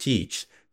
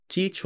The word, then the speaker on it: Teach: Teach was likely pronounced much like Thatch, and the changing form of the name should not be taken as evidence that several different men stood behind the legend.